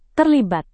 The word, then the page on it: terlibat: indonesian